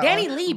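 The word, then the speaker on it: DaniLeigh: Like, okay, for the listeners, we're referring to Danny Lay and He Want a Red Bone. – Danny Lee, bitch. – Danny Lee, Danny. Okay, sis. I don't like silent G's and H's and shit.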